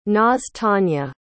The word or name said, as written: nastunye